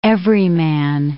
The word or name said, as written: everyman